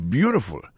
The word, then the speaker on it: beautiful: Et moun qui dit ça, li dit élément qui t'a vu musique là, that was beautiful. Qui fait musique ou t'a vu à tes belles en pile, that was beautiful. Gardez pour voir que l'accent tombé sur le premier syllabe là.